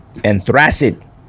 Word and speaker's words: anthracite: Anthracite. – I think that's wrong. I think both those are wrong. – It's anthracite. – Anthracite. – Y'all been saying anthracite since 2018.